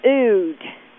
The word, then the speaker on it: oud: I hope I'm saying it right, and he plays the oud. Rhymes with food.